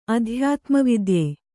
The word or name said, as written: ಅಧ್ಯಾತ್ಮವಿದ್ಯೆ